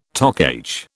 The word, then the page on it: Toc H: British English